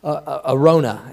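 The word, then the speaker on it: Araunah: That's the same guy as Arunah. So just Arona, actually, is how you say it.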